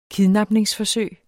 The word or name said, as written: KIDNAPPES